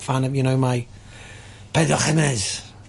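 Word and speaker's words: Pedro Ximénez: Pedro Jimenez.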